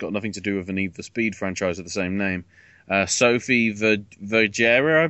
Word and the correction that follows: Sofia Vergara: I believe. Is that how it's pronounced? – Sofia Vergara. – Sofia Vergara, okay.